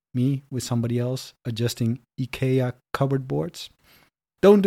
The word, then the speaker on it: IKEA: And IKEA, by the way, is the correct pronunciation. All right? Not IKEA.